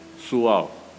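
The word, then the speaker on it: 蘇澳: 苏澳。苏澳人讲苏澳不像我们讲苏澳，那个澳不发音，他们是 so so 手，哎，很像按呢。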